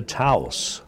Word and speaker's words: Taos: $100 came in for the VCY General Fund from Ranchos de Taos, New Mexico, T-A-O-S. I hope I'm saying that right, Taos. – Sounds good to me, Doctor.